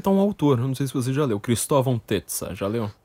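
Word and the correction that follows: Tezza: O Cristóvão Tessa, acho que é, se pronuncia Tesa.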